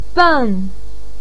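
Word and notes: beng4: tone 4

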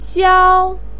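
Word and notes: xiao: tone 1

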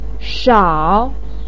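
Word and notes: shao: tone 3

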